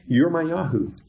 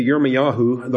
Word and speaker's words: Yirmiyahu: But on the splash slide there, that the best pronunciation of Jeremiah is Yirmiyahu. Okay? Yirmiyahu. And we see it there. So they said to Yirmiyahu, the prophet, we beg you, let our petition be acceptable to you, and pray for us to Yahweh, your Elohim.